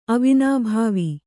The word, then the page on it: ಅವಿನಾಭಾವಿ: avinābhāvi